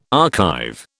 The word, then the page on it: archive: British English